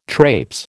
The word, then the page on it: traipse: American English